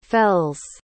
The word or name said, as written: fels